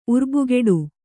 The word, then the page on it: ಉರ್ಬುಗೆಡು: kannada